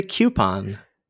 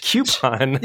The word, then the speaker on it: coupon: It's a coupon. – That's how you say the word. Coupon. – Yeah, coupon. Not a coupon, but a coupon.